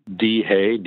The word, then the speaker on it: Dhay: they could just email me at Dhay, D-H-A-Y, nice short last name, Dhay at Evergreen Capital or GavCal, either way.